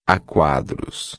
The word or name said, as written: a quadros